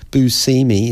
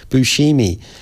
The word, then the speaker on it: Buscemi: He pronounces his own name wrong. Buscemi is how he pronounces it, but in Sicily they pronounce it Buscemi. – Oh. – So he said, I had to go home to find out that I was pronouncing my name wrong.